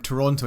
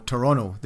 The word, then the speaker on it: Toronto: Toronto. And he goes, no, Toronto. They don't pronounce the second T.